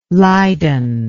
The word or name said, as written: leiden